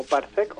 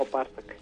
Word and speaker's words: parsec: La pronunciación es válida para parsec como parsec o parsec. – Ah, ok, perfecto. – Los dos son aceptados, ya lo chequeé una guía de pronunciación que tengo aquí que me saca de apuros a veces.